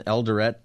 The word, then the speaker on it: Eldoret: It's in Eldoret, I think is how you say it, Kenya. – That's right.